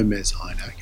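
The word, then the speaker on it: Mes Aynak: And if you don't know Mez Ainak, it's spelled M-E-S. The new word Aynak, A-Y-N-A-K.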